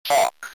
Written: talk

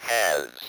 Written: has

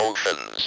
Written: oceans

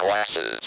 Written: glasses

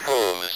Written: hooves

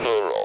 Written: plural

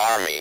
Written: army

